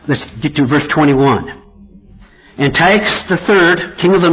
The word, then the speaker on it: Antiochus: Antiochus is spelled A-N-T-I-O-C-H-U-S. A-N-T-I-O-C-H-U-S.